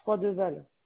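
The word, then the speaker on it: Froideval: Froid de Val. – Comment ça s'écrit? – F-R-O-I.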